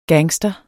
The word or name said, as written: gangster